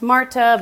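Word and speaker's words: Märtha: But it's called Atlantic Crossing, and it's about a World War II relationship with FDR and Norwegian Crown Princess Martha, which he said is pronounced Marta, but I wasn't trying to get into accents.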